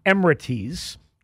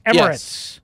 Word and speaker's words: Emirates: But my dopey friend Fred texted me because he's got absolutely no life, and he informed me, quote, it's pronounced Emirates, you doofus. – Emirates. – Emirates. – Yep. – You doofus is what he called me. Not Emirates. Emirates.